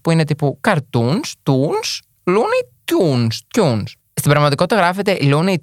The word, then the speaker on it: Looney Tunes: Γιατί εγώ, όταν ήμουν μικρό, το έλεγα Πάμε να πάμε να δούμε τα Looney Tunes. Τα έλεγα έτσι. Ε, Παρ' όλα αυτά, εντάξει, η ζωστή προφορά προφανώ είναι Looney Tunes.